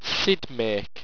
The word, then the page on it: sidmaque: owstwecian